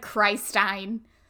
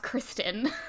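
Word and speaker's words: Khrystyne: It looks like Christine, but I'm pretty sure it's pronounced Kristen.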